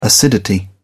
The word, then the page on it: acidity: British English